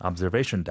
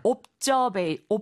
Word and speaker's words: observation: Observation deck. – Observation deck. 이거 sure. – 옵저베이, 옵저, 앞저 이렇게 말씀하시면 잘 못 알아듣고요. zer 해서 z 발음이 됩니다.